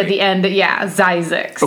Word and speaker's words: Zzyzx: He names the settlement Zizek's. Which rhymes with Isaac's. – Yes, yes, it does. Says, and is spelled, you ready for this? Z-Z-Y-Z-X.